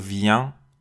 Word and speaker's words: viens: سه تا شخص اول آخرشون نزله آخرش رو باید توی بینی تلفظ بکنیم je viens